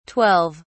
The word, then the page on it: 12: British English